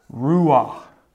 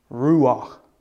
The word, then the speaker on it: Ruach: It's this, Ruach. R-U-A-C-H. Ruach. Ruach. Okay?